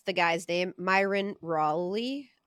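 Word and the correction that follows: Rolle: R-O-L-L-E. – I thought it was Myron Roll.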